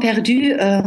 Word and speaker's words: Perdu: Am I pronouncing that correctly? – Nearly. It's Jean Perdu, comme les mots français, like the French word for lost, perdu.